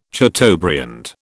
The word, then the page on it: chateaubriand: British English